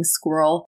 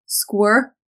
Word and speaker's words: squirrel: I always say the easiest way to pronounce words that are really complicated, especially with the dark L, is to divide them into two. Squirrel.